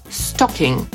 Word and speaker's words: stocking: No więc po brytyjsku te pończochę wymówimy jako stocking, czyli tam jest po prostu zwykłe, krótkie o, tak jak w słowie pot na przykład, które znaczy i garnek, i marihuana.